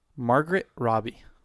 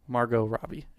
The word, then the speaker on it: Margot Robbie: Margot Robbie. – Oh. – Margot Robbie is the correct pronunciation.